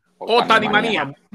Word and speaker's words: Otanimanía: la Otimanía, ¿se dice así? – Otanimanía.